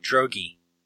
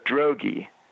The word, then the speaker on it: Droege: He pronounces his name Droege. – Is it? So it's Droege. Okay, well, that makes sense.